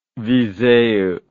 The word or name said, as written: viseu